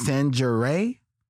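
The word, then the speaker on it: Sangerye: In the 1920s, the Harlem Renaissance is in full swing, and only the I always want to pronounce the name wrong. It's Sanjuray.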